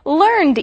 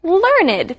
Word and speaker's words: learned: Now, you'll notice that here this word has two syllables. It's not with learned ease. It's learned because this is a different part of speech, right, Gabe? – That's right.